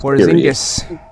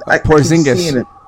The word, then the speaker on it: Porzingis: Porzingis. – Say it again. How you pronounce it? – Porzingis.